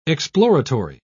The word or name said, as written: exploratory